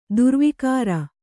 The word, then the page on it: ದುರ್ವಿಕಾರ: kannada